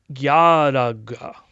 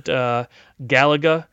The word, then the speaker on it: Galaga: Galaga, or as the Japanese say it, Yaraga. – Plaid Mouse. – Yeah, in there. – So. Yaraga. – Kind of interesting. Pronounce it Galaga. I guess if you wanted to flatten it out, Galaga is fine. But yeah, the Japanese actually say Yaraga.